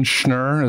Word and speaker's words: Schnur: Yeah, so Jon Schnur is how you pronounce my last name. That's step number one. – Like Schreiner. – That's right.